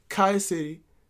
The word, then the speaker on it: Collier City: It's spelled Collier City, but the real niggas pronounce it Kaya City from 6th Street off 27.